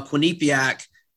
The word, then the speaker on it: Quinnipiac: They play uh, Quinnipiac, and yep.